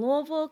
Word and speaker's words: Louisville: I actually did an internship in Louisville, Kentucky. Yeah, you gotta say it like you gotta say Louisville.